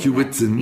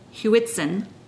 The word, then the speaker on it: Hewitson: And it's Hewitson, I should say. – Hewitson. – That. Yes, I always say that wrong. Hewitson. And she was actually, so Miss Harry Hewitson was a pioneer in the wine industry in Australia before it came of age, the late 1800s to early 1900s.